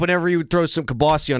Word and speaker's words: kielbasa: Is it Kielbasa or Kielbasi? – It's Kielbasi. – Kielbasi. – Even though it's spelled Kielbasa.